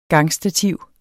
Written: gangstativ

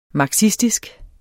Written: marxistisk

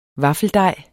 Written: vaffeldej

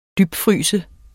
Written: dybfryse

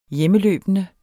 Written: hjemmeløbende

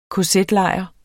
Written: kz-lejr

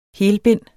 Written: helbind

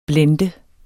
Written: blender

